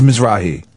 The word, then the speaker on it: Mizrahi: How do you pronounce his name? – Isaac Mizrahi. – Make sure I pronounced it right when I fucking did it. – Now we've said it too many times. It carries no meaning. I don't know what it is. – It's Mizrahi.